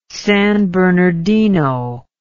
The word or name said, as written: San Bernardino